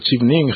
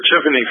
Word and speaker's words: Chevening: Alors, pouvez-vous nous présenter cette bourse Chevening? Je crois que c'est bien prononcé. – Oui. Je pourrais dire que The Chevening Scholarship, c'est un programme qui a commencé en 1983.